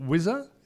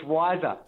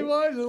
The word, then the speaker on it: WISR: Now, but also, even the business you work for, is it Wizzer? Is that the right pronunciation? W-I-S-R? – It's Wiser. – There you go. – Actually. – See, Wiser. Like, smarter, cleverer. It's Wiser.